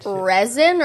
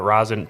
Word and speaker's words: rosin: R-O-S-I-N, right? – Resin or rosin? – No, it's rosin. – It's rosin. Now, resin is...